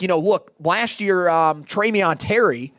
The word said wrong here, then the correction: Tamorrion Terry: or uh, excuse me, Tamorrion Terry, I believe is how you pronounce the name.